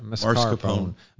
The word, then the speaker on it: mascarpone: Mascarpone.